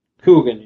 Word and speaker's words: Coogan: Uh, uh I can't pronounce Jackie Coo, Coo, Coogan. – Coogan, yeah.